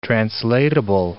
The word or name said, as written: translatable